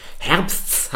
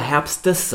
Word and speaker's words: Herbsts: Herbsts hat ganze fünf Konsonanten nach dem Vokal, das ist super schwer auszusprechen. Die meisten auch von uns werden eher Herbstes sagen, lösen sogar dieses Wort aus, machen daraus zwei Silben Herbstes, um es richtig auszusprechen.